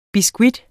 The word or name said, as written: BISKUITTER